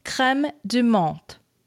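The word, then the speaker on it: crème de menthe: Correct French pronunciation is creme de menthe, with a T, not a th.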